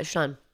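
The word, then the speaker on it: Siân: Yeah, Sean.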